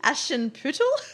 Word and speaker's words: Aschenputtel: That story called, and I'm so going to screw this up. – Go on. – Aschenputtel. – Nice. – It's spelled Aschenputtel. – All right, I'm not going to. – Aschenputtel. Yep.